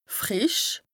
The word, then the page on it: frìsch: Bas-Rhin; Strasbourg